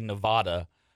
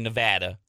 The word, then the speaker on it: Nevada: people who say Nevada, it's actually Nevada.